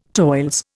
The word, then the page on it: toils: Indian